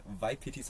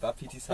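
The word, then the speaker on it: Wapiti: Also gestern gerade hatten wir richtig viele, wie ich es immer ausspreche, Waipitis, obwohl sie Wapitis heißen.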